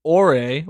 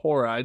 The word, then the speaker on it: Horai: Ore or Horai, depending on how you want to pronounce it, two different versions.